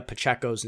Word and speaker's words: Pacheco: First and foremost, I need to apologize because I've been pronouncing Isaiah Pacheco's name wrong for the last like 3 weeks. It is Pacheco, Isaiah Pacheco.